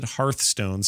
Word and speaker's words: Hearthstone: We pronounce it Hearthstone, same as you.